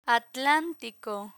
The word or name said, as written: atlantico